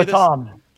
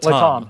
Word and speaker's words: LATAM: How do you say LATAM. – This? LATAM.